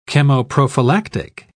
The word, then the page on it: chemoprophylactic: British English